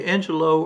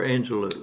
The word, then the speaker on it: Angelou: Do you say Angelou or Angelou? – Either.